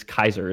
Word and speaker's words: Caesar: It is Kaiser is the correct pronunciation of Tiberius Julius Kaiser.